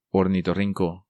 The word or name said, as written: Ornitorrinco